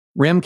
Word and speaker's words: Rehm: And now, and I'm going to mispronounce your name, Rehm, R-E-H-M. Would you concur that that's pronounced Rehm? So that if it's a mistake, it's both of our mistakes.